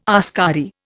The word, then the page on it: askari: Indian